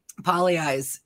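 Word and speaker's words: Pagliai's: Poly Eyes. It's spelled Pagli Eyes, but Poly Eyes in Mankato.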